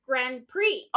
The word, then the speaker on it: Grand Prix: How do you say it? Grand Prix. – Grand Prix. – Oh, it's a Grand Prix. – Yeah. – Well, then they should spell it P-R-E-E, not P-R-I-X.